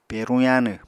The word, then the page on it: peruñ yaanw: Apatani